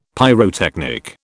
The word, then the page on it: pyrotechnic: British English